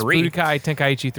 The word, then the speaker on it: Budokai Tenkaichi: So, at number 19, they did have Dragon Ball Z, I'm not going to pronounce this right, Budokai Tenkaichi 3. – It's Budokai Tenkaichi 3.